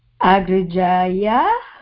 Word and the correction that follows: Agrajaya: By means of one elder sister. – Agrijaya. Agrijaya. – Agrajaya. – Pronouncing that wrong.